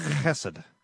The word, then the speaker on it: Chesed: Chesed. Must be pronounced that way every time.